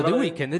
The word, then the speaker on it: The Weeknd: Allora, qui io li chiamo The Weeknd, ma in realtà non si chiamano così. Si chiamano The Weeknd. – No, The Weeknd è giusto. – The Weeknd.